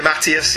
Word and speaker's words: DeMatteis: DeMatteis, however you pronounce DeMatteis. – It. DeMatteis, yeah.